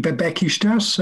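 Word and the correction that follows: Beşiktaş: Besiktas. I know it's Besiktas, isn't it? – It is Besiktas.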